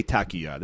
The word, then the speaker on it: Takuya: I'm going to say Takuya. That's how you pronounce it.